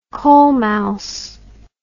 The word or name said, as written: colemouse